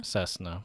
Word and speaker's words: Cessna: Cessna.